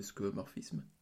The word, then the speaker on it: skeuomorphisme: des applications d'Apple était, euh, enfin, rappelait beaucoup le réel, quoi, le, fameux skeuomorphisme. C'est ça, je le dis bien euh, oui.